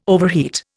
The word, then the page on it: overheat: Indian